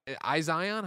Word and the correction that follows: Ixion: How do you say this one? You think? – Is it Ixion? – Ixion. Okay. Marvel's Midnight Suns, Potion Craft, Alchemist Simulator.